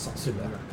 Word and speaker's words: Saint-Sulac: So it's Saint and then S-U-L-A-C, which in French is pronounced Saint-Sulac.